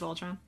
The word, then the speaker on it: Voltron: I said that right. It is Voltron.